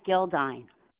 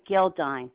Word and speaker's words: Gildine: My name's Janet Gildine. – Can you spell it for me? – Sure. J-A-N-E-T. Janet Gildine. G-I-L-D-I-N-E. D as in David, E-I-N.